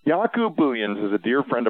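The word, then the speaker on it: Jacob: His name is spelled J-A-C-O, Yaku, pronounced like Yahoo email without the H. So Yakub Buyans, B-O-O-Y-E-N-S.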